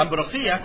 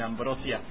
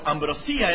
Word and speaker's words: ambrosía: La ambrosía, con acento en la I, yo sé que algunos están pensando en Ambrosio, en ambrosia, pero ambrosía era una comida y satisfacía, según la mitología griega, satisfacía como nada en el mundo, deliciosa.